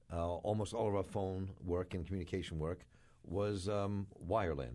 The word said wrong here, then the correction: wireline: Did I say it right this time? – Wireline. – Wireline. Thanks, man.